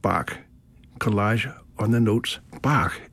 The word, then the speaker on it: B-A-C-H: Bach, but the musical tones B-A-C-H, or to pronounce them in German, B-A-C-H. B in German is not B, it's B-flat. So it's B-A-C-H.